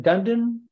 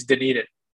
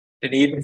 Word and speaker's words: Dunedin: Is it Dundon, Florida? Is that how you say it? – So Dunedin. I believe me. I looked at them like Dunedin, Dwayden. Yeah, it's Dunedin. Dunedin, Florida.